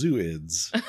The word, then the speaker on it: zooids: Yes, spelled Z-O-O-I-D-S. – Okay. – So at first I thought that was zooids. But upon a quick search, it seems to be pronounced zooids.